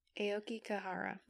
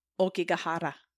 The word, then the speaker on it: Aokigahara: Aoki Kahara. – That is correct. – Good job. – I think the authentic pronunciation is like Okigahara, but I cannot do it like that.